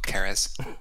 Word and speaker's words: Ceres: I believe it's pronounced Ceres, but okay. – Or Keres. It's always a hard K.